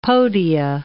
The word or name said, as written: podia